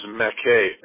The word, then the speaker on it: Mackay: And the street is Mackay. M like Mary, A-C-K-A-Y.